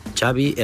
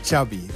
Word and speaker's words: Xavi: Xavi Hernandez. – Xavi.